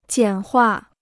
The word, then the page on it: 简化: jiǎn huà